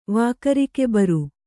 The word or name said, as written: ವಾಕರಿಕೆಬರು